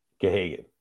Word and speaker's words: Gahagan: I, I, wouldn't say he's the backup because he's kind of sh- sharing the load with Parker um, Gagan. Is that how you say it? Gahagan. Gahagan. Yeah. H was silent.